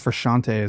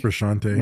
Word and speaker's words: Frusciante: I think it's just Frusciante is how you say that. I think Frusciante.